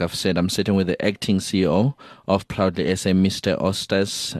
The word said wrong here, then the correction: Eustace: Mashimbe. I hope I pronounced that correctly. – It's Eustace. – Eustace. Oh, Eustace Mashimbe.